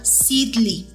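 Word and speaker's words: Sidly: Es S de sal, I latina, D de dedo, L de león, Y. Se llama Sidley, perdón, si lo dije mal, se llama Sidley.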